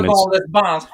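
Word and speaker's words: Yverdon-les-Bains: Yverdon Les Bains is the town. – Yverdon Les Bains.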